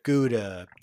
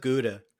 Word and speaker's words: Gouda: Gouda. – Gouda. – Which isn't that pronounced some other way? – I think it's pronounced Howda.